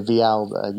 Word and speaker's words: Villalba: If we pronounce it the Spanish way, Villalba, you don't have to listen to that bit.